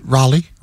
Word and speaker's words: Raleigh: The mayor of Raleigh, how do you say that city there? – It's just Raleigh.